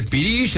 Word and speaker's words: pirix: Pirish, se dice Pirish, es una palabra muy hermosa.